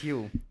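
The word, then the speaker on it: Hue: Ik zei altijd Jui en het is U. – Het is U.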